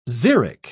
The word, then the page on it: xeric: British English